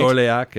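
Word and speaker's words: Dorléac: Dorliac, yeah.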